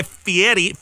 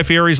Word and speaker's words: Fieri: She says it's, I want to say Fieri, but it's Fieri.